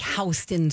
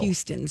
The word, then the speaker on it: Houston: It's one of those regional things, you know, like Houston Street in New York instead of Houston Street. – They call it Houston. – It's Houston.